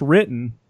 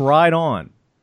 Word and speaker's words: Riton: And it's R-I-T-O-N. It's not pronounced written. It's pronounced Ride On.